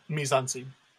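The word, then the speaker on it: mise-en-scène: just your mise-en-scene. It's a French term that I'm pronouncing completely wrong, but mise-en-scene. – Sure. There you go.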